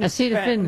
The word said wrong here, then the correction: acetaminophen: How do you say it? Acetaminophen, sir. Acetaminophen.